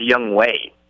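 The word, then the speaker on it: Younghoe: And then I had to look to see how the name was actually pronounced, and I think it is Young Huey is how it's pronounced. – So I just looked at it. It's Young Way.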